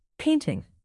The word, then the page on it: painting: noun